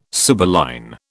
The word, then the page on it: sibylline: British English